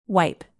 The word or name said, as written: wipe